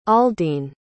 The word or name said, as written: aldene